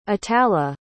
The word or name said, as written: atallah